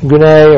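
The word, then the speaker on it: gune (γυνή): The word here is, the Greek word is gune, or G-U-N-E is how you spell it.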